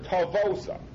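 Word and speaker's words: Tavosa (תבואתה): Now this is a very funny word, Tavosa.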